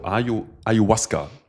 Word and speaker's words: Ayahuasca: Also Ayahuasca, so spricht man es aus, wird geschrieben A-Y-A-H-U-A-S-C-A. Ayahuasca.